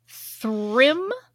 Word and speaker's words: Thrym: There, Thrym, mm-hmm. Is that a good pronunciation? Yeah. Mm-hmm.